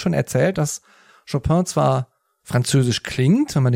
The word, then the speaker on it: Chopin: Er wollte auch immer übrigens polnisch Chopin ausgesprochen werden.